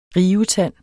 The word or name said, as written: rivetand